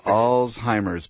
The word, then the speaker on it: Alzheimer's: I like to say, remember All's Fair. – Alzheimer's, but it's a very commonly mispronounced word, but it does draw attention to the disease, so I guess that's not the worst thing.